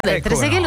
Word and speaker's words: Trezeguet: Trezeguet, l'ho detto giusto, però sì, vedi, sì, ok, sì.